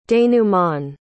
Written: denouements

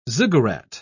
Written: ziggurat

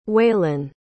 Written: whelan

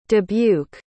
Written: dubuque

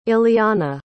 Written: ileanna

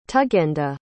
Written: tugenda

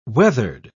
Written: weathered

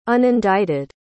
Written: unindicted

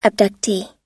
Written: abductee

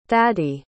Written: thady

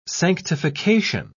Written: sanctification

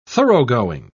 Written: thoroughgoing

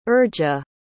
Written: urja

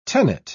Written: tenet

